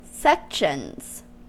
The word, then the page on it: sections: American English